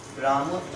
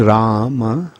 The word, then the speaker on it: Rama: Generally, the word is Rama, Rama, but then it is sometimes Ram. Like in addressing, it is sometimes pronounced as Ram. Ram, Ram. Hmm? Either way, it's all right.